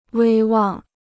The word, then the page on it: 威望: wēi wàng